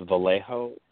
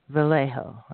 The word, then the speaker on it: Vallejo: It's pronounced Vallejo. The J is silent. – Vallejo.